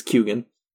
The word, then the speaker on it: Keoghan: I think it's Keoghan. He says that the G is in there. He pronounces the G. And so I'm sorry, Irish listeners, and I'm sorry, people who don't know that he pronounces it with a G.